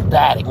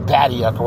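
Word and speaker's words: Batiuk: It was one of those things like, hey, asshole, the name's fucking Baddick, not Baddiuck or whatever you were trying to say.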